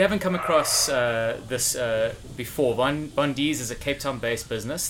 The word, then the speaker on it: Vondis: the owner of Vondis. Is that how you pronounce it? – That's it, Vondis.